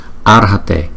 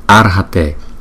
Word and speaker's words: Arhate: Arhate, Arhate, Arhate. Cái chữ R này chúng ta đọc là chữ R, chữ ra nhưng mà không có đọc rõ chữ ra mà là Ar, Arhate, Arhate, Arhate.